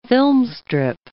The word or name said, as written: filmstrip